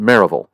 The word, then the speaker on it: Maryville: M-A-R-Y, Maryville, Tennessee. But if you're from Tennessee, we used to pronounce it Maryville.